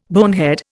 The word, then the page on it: bonehead: Indian